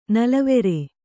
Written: ನಲವರಿ

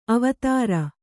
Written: ಅವಧಾರು

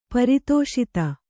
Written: ಪರಿತೋಷಿತ

